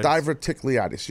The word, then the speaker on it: Diverticulitis: Diverticulitis. Did I say it right? – Diverticulitis.